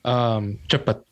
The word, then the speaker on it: cepat: We say cepat. So I think it's spelled C-E-P-E-T or something like that. Because in Indonesian, you guys have T-S-E for like the ch sound, the C-H sound.